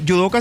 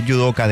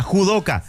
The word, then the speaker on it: judoka: porque yudoka se escribe con J, ¿verdad? Pero se lee yudoka del deporte judo. – Claro. – Y en la inteligencia artificial leía judoka, judoka querido.